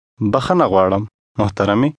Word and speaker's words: bakhana gwaalam: Bakhana gwaalam, muhtarami. – This expression contains sounds that do not exist in English. Repeat after the speaker, trying to make your pronunciation sound like his.